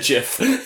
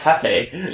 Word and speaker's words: Jefe: Is one spelled J-E-F-F and the other one's G-E-O-F? – Oh, Jeff. No, it's J, it's G-I-L. – Jeff. – There's another guy that his name is spelled J-E-F-E. – That's Hefe. – Yeah, I know. But he pronounces it Jeff.